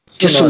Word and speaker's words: Tsunoda: E tem uma possibilidade de daqui a pouco o Tsunda apareceu, acho que é essa pronúncia, sou péssima em pronúncia Tsunoda. asiática. Tsunoda, Tsunoda. Isso.